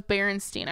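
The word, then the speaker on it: Berenstain: The film was, in part, an inspiration for the father and son in the Bernstein Bears books. – You're getting crazy with these fun facts. – I loved the Bears. Oh, it's Berenstein. I always say it wrong.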